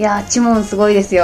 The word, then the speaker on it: 朱蒙: で種猛と書いてチモンって読